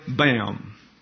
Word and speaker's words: Boehm: Lieutenant Commander Roy Bam. I'm sorry, I don't know how to pronounce his last name. It's spelled B-O-E-H-M. The Merriam-Webster's Dictionary of Biographical Figures gives this pronunciation.